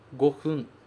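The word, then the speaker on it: gofun (五分): Eu posso usar o kanji de 5 e o kanji de minuto para formar a palavra gofun.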